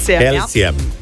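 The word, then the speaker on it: Kelce: Taylor Swift se je petek poročila z nogometašem Travisom, a se izgovori Kelsijem, ne? – Kelsijem.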